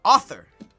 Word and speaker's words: author: Author. – What? – You got to put that U in there. Author. – What did I say? – Arthur. – You're a liar. – A-R-T-H-U-R.